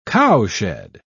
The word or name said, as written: cowshed